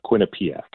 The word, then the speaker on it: Quinnipiac: but I believe that you are talking about Quinnipiac.